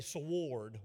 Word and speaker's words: sword: Or if you're from East Tennessee, sword, we pronounce that W in there.